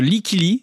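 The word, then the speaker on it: Lykke Li: Dans Soundcheck ce matin, une collaboration inattendue, celle de la chanteuse Like Lee et du producteur The Magician, car Davy Bay Bazin, pendant longtemps, ces deux-là étaient en profond désaccord. – Oui, en effet, Likili, c'est comme ça qu'on prononce,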